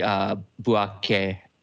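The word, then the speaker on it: Bouaké: Bouake in the north, both cities celebrating equally. I think I got those pronunciations close enough. – Yeah, those are pretty good. Those are good.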